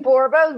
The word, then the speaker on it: Borba: Last name Borba rhymes with Zorba.